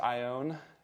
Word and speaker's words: Ione: So it's pronounced Ione. California. – I-O-N-E, yep.